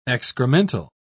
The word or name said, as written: excremental